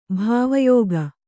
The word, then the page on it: ಭಾವಯೋಗ: bhāva yōga